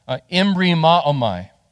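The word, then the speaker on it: embri ma'omai (ἐμβριμάομαι): And transliterated, it's pronounced embri ma'omai.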